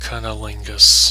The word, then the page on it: cunnilingus: American English